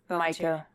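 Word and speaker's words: Mico: And is it Miko or Miko? – Miko. – Miko Volunteer Fire Department is doing the election on election day at their facility.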